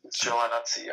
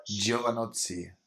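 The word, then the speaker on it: Giovinazzi: Ferrari Motor, Antonio, äh, Antonio Giovanazzi, äh, Gio, oh Gott, wie heißt der Kerl? – Giovanazzi, ja. – Giovanazzi. So, jetzt ist richtig ausgesprochen.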